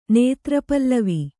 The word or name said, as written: ನೇತ್ರಪಲ್ಲವಿ